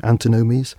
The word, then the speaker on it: antinomies: I think that's the way you pronounce it, don't you? Antinomies? – Yeah, antinomies, whatever.